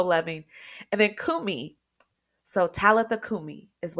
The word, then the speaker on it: Talitha: And just real quick, I'll tell you that um, it's actually pronounced Talitha in the Greek.